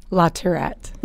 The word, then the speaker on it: LaTourrette: LaTourrette.